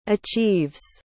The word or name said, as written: achieves